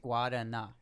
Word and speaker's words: Guarana: Guadana. Me and her went back and forth voice messaging, and she that, yeah, she said that I'm pronouncing it right.